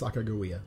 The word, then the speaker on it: Sacagawea: They didn't know the way, of course, so they enlisted the help of a 15 year old young lady named Sacagawea. And I'm told, despite what I might have been taught, uh, taught by the Schoolhouse Rock people, that that's the way it's properly pronounced Sacagawea.